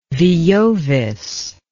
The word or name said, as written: veiovis